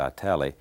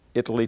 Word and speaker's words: Italy: There's a little town between Dallas and Waco called, I pronounced it Italy, I think the residents pronounce it Italy.